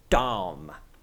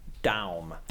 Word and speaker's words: Daum: Okay, so Daum. Okay, so that's my understanding of it. It's like down, but with an M. Daum.